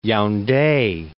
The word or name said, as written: Yaunde